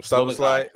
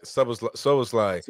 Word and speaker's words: Szoboszlai: Szoboszlai. – Szoboszlai.